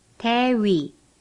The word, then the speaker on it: 대위: The Korean T sounds like that in bit, but it is pronounced without expelling any air. For example, 대위.